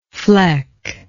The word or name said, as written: flecky